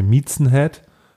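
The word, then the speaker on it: Mizen: Aber ich habe so ein, zum Beispiel so ein, ähm, ich weiß nicht, ob ich es richtig ausspreche, Miezenhead.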